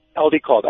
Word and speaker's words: Aldicarb: And Aldicarb. – It's very easy to obtain. Aldicarb. Yes. – How do you say it? – Aldicarb. A-L-D-I-C-A-R-B.